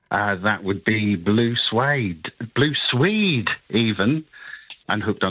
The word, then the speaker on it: Blue Swede: It's a Swedish band, spelt Swede, but pronounced Suede.